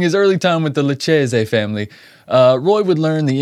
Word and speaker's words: Lucchese: I think it's the Lucchese? – Is it the Lucchese? – Lucchese. – Oh my God, I've been saying it wrong all these years.